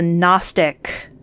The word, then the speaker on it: Gnostic: That's a Gnostic gospel with a silent G.